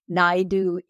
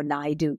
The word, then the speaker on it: Naidu: Naidu is like can do, you know, Naidu can do.